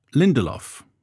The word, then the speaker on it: Lindelof: Lindelof. – It's just it's three syllables, and I was expecting it to be two.